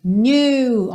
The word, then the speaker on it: knew: Sweat dripping off me, before I even knew her name, knew, ok? O passado do verbo to know, knew, e o K é mudo, assim como no presente, know, ok?